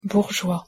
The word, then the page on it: bourgeois: French